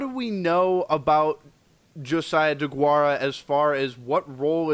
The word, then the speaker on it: Deguara: Am I pronouncing Deguara.